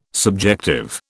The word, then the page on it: subjective: British English